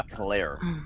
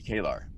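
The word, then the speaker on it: K'Ehleyr: And then, like, I was like, okay, that's Kalar, because K-apostrophe-E-H-L-E-Y-R will never... In any language from the planet Earth, be Kalar. But okay, Kalar it is.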